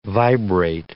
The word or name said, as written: vibrate